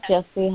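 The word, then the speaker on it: Jessie: Yes, this is Jesse Harden.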